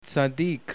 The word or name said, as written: tsadik